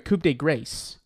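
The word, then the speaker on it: coup de grâce: Coup de grace.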